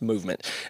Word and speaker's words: MVMT: I got to interrupt you because I got to tell you about Movement Watches, MVMT Watches, pronounced Movement.